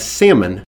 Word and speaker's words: Salmon: His father's name would be spelled like salmon. Salmon is how you'd pronounce it in Hebrew. – S-A-L-M-O-N. – S-A-L-M-O-N.